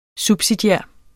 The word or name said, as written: subsidiær